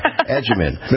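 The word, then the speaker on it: Agyeman: Adjaman. Phonetically figure that out like we would use in Italian, agita.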